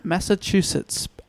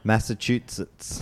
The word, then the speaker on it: Massachusetts: So Edgar Allan Poe was born on January 19th, 1809 in Boston, Massachusetts. I'm pronouncing that obviously incorrectly. How do I say that? – Massachusetts. – Thank you so much.